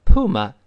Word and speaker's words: puma: 再说再顺便说一句啊，有同学说老师美音中美洲狮不是读成 puma 吗？确实，在美国两个发音都对，puma puma，哎，这都是对的。那 a puma at large，陶顿的美洲狮里就出现这个 spot。